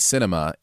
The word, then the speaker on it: Sinema: Was eating at Cinema in Nashville. – Spelled S-I-N-E-M-A. – E-M-A. Pronounced like enema.